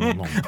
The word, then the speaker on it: Lindon: Sure. Vincent Lindon. – That's exactly how you say it.